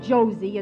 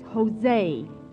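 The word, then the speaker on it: José: It's not Josie, it's Jose. In Spanish, J is pronounced like H.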